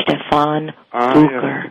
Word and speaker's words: Stefan Bucher: It's Stefan Bucher.